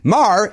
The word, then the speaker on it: Maher: Maher, it rhymes with star.